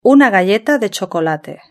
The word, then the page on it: una galleta de chocolate: European Spanish